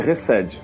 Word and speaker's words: Chesed: Errei muito a pronúncia? – Muito bom, muito bom. É Netzah e Resed, só. O resto tá certo.